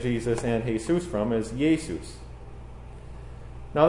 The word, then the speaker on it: Iesous: Now in Greek, that's Iesus. You spell that I-E-S-O-U-S. Iesus. Maybe it sounds more similar to the Spanish Jesus than it does to our English Jesus.